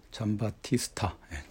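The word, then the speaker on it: Giambattista: 역사고전 강의 제19강부터는 저기 잠바스타 비코, 잠바스타, 잠바티스타, 잠바티스타. 이 잠바티스타를 발음하기가 좀 어렵죠?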